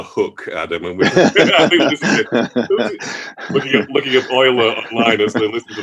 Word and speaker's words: Euler: with which is the Euler identity. Is that the correct pronunciation? – Yeah, Euler's identity, as in the identity belonging to Euler.